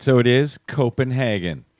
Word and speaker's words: Copenhagen: By the way, it is pronounced Copenhagen, not Copenhagen, but that's only if you believe the Danish embassy.